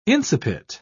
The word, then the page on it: incipit: British English